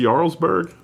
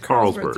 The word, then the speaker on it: Carlsberg: So is this pronounced Jarlsberg? Is that what that is? – I think it's just Carlsberg. – It's Carlsberg. There's a C.